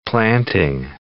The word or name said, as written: planting